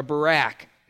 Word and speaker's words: Barak: And it says, for she sent and called for Barak, or Barak is really the right pronunciation of that.